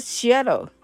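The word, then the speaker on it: Seattle: このシアトル、まあ、発音はシアロですけれども、シアトルのね、名前は、いわゆるネイティブアメリカン、というね、方たちの、そこのね、シアトル州長という一番偉い人です。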